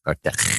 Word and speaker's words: Cartagena: And we visit places like Cartagena. – What the fuck was that? – That's how you say it if you speak the Spanish. Oh. You say Cartagena.